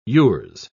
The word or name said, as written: yours